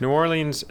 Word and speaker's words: New Orleans: New Orleans, as I'm saying it correctly, by the way, New Orleans.